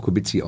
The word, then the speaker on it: Kubicki: Polnisch würde ich auch Kubicki ausgesprochen. – Ja, aber... Ja. Also wenn Sie in Polen sind und Ihren staatsmännischen... – Dann werde ich Kubicki angesprochen.